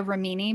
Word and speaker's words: Remini: I want to call her Leah Ramini because her name is Italian, and if you speak Italian, that's how you would pronounce it. But everyone says Remini anyway. – Yeah. – Just want to put that out there. it's her last name. She can pronounce it how she wants. It's just also wrong.